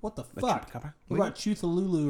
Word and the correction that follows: Cthulhu: It's Cthulhu, dude.